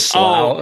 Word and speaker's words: slough: And the word that's used there is spelled S-L-O-U-G-H, that word of despond. How do you guys pronounce that word? – Slough is how I pronounce it. – The slough of despond.